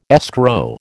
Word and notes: escrow: American English

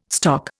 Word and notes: stock: Indian

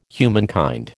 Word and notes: humankind: American English

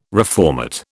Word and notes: reformat: British English

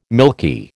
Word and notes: milky: American English

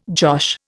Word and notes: josh: Indian

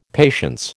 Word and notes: patience: American English

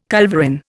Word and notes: culverin: Indian

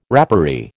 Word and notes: rapparee: American English

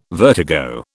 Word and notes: vertigo: British English

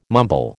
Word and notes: mumble: American English

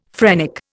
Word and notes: phrenic: Indian

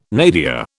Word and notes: nadir: British English

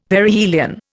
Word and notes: Perihelion: Indian

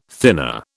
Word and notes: thinner: British English

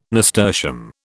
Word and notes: nasturtium: British English

